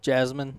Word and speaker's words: jasmine: How do you say that? – Jasmine. What? Yeah.